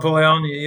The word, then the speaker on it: Polanyi: Karl Polanyi, yeah.